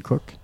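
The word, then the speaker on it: Koch: cock K-O-C-H, c'est Cook.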